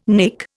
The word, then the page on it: nek: Indian